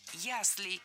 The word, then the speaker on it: ясли: Было сказано «система ясель», но это неверно. Правильный вариант – «ясли» с ударением на первом слоге и окончанием «ей». Что интересно, во многих словарях даже есть предупреждение. «Ясель» и «яслей» – неправильно, только «ясли».